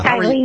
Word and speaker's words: Stively: Stively, yes.